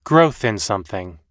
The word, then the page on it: growth in sth: American English